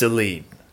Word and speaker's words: Eline: It's a vowel sound. It's either Eileen or Eline. – I would take both answers because it's Eline.